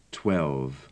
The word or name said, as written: twelve